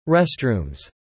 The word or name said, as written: restrooms